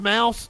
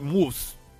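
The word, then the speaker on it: Maus: It's pronounced Mouse? Okay. I didn't know if it had like a German like Moose.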